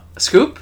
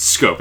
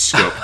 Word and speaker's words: Schoop: Scoop? – Nope. It's Scope. It's pronounced Scope. like the mouthwash.